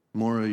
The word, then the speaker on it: Maura: Maura Yu. It's pronounced like Laura, but with an M.